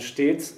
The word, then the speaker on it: stets: which is W-A-R with at the H. In the second line of this, we get one of the exceptions to the anything with two consonants in the stem is a short open vowel sound with the word S-T-E-T-S. – Stets. – It's a long closed E, right? – Yeah.